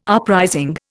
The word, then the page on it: uprising: Indian